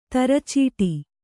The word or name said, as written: ತರಚೀಟಿ